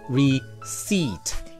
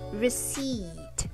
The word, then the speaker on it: receipt: 对，这是一个属于最高频译错的发音之一，因为这个词中间的这个 P 是不发音的，读作 receipt. – Yep, receipt.